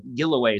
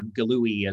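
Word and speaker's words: Giluwe: But Gilloway is probably the or Gillooey as the Brits say.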